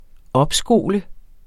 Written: opskole

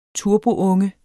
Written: turbounge

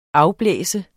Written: afblæse

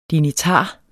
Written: dignitar

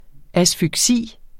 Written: asfyksi

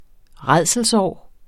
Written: rædselsår

